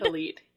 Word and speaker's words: Khalid: Khalid, yes.